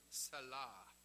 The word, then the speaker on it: Selah: And the first is the Hebrew term Selah, if you pronounce that in the Hebrew way.